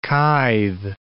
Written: kithe

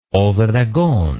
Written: obregon

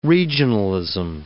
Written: regionalism

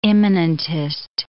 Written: immanentist

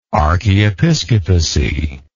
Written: archiepiscopacy

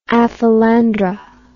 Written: aphelandra

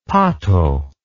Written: pato

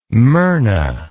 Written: myrna

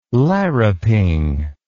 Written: larruping

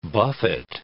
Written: buffet

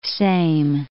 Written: Seim

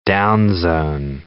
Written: downzone

